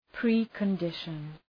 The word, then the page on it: precondition: ,pri:kən’dıʃən